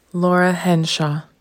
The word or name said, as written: Laura Henshaw